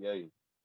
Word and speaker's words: Gueye: What it sounds like on draft night is Mouhamed Gueye. – Mouhamed Gueye, got you.